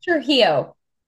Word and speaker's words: Trujillo: How do you pronounce it? What's the surname? – It's Trujillo. – Trujillo.